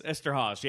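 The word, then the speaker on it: Eszterhas: Esterhaas? – Esterhaas, yeah.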